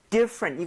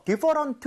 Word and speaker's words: different: 이 different 할 때 많은 분들이 강세를 잘못 줘서 different 사음절처럼 발음을 하시더라고요. 근데 그게 아니라 i의 강세를 꽝 찍고요. – 네. – 거의 t는 흡수되는 느낌이에요.